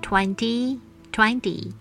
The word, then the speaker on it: twenty: 是 twenty, twenty，还是 twenty, twenty？拼字是 t w e n t y 的这个字，重音节的发音状况跟 ten 一样，字母 e 发的是 a a，所以二十正确的声音是 twenty twenty。